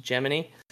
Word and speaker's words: Gemini: Geppetto, Jiminy, which in this case is written Gemini, but still pronounced Jiminy.